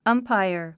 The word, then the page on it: umpire: ʌ́mpaiə r; アンパイア